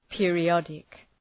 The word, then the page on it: periodic: ,pıərı’ɒdık